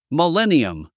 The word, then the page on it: millennium: miléniəm; ミレニアム